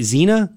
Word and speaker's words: Xenia: And the person's name, by the way, I don't know how to pronounce this, so I'm going to just take a guess. X-E-N-I-A, Xenia? – Xenia.